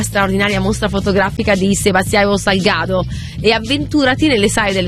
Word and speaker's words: Salgado: Tra l'altro è Salgado è la giusta pronuncia.